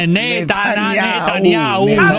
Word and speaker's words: Netanyahu: Netanyahu.